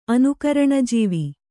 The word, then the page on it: ಅನುಕರಣಜೀವಿ: Kannada